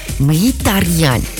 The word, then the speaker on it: Mkhitaryan: Mkhitaryan, cu H-ul mai pronunțat așa.